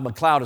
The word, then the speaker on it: McLeod: McLeod is spelled M-C-L-E-O-D, not like cloud like up in the sky.